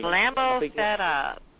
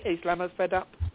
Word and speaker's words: Islamo-fed up: I think most of us are just Islamic fed up with this whole thing. – Islamo fed up. You better believe it. – Thank you. Is that Islamo fed up? Go ahead. Did I pronounce that right? Oh, don't want to mess things up. – Oh, yeah, you got it right.